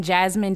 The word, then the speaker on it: Jazmyn: You can follow us on Instagram at Colored Couch Conversations, and you can also watch the show on my YouTube, Comedian Jasmine W on YouTube, and my name is spelled J-A-Z-M-Y-N.